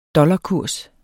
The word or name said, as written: dollarkurs